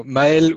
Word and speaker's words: Mel: Yeah, that's about right, but it's hard to pronounce, you know, so Mail would be the correct way to pronounce it, but you know, no hard feelings.